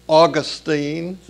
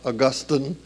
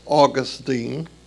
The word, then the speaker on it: Augustine: He's now, as I said, called Saint Augustine. That's the correct pronunciation of the word, not Augustine. It's Augustine. He